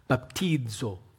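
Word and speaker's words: baptizo (βαπτίζω): If I was going to say it right, it'd be baptizo.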